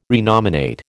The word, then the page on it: renominate: American English